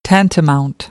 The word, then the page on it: tantamount: American English; ɾ